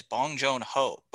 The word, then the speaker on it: Bong Joon-ho: The director of Parasite is Bong Joon-ho. – What did you say? – Who? – Oh, okay. – That's how you pronounce his name.